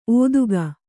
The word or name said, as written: ಒದಗೆ